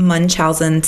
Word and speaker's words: Munchausen: Yeah, and the one that's not by proxy, how do you pronounce it again? – Munchausen syndrome.